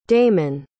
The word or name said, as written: damon